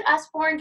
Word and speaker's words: Usborne: Is it Usborne? Do I say it right? – Yeah, that's how you say it.